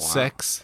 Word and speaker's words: CeX: I sold that, though, to CEX, you know, the shop. It was got like 12 euros. – It's pronounced sex.